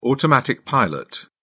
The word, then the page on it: automatic pilot: British English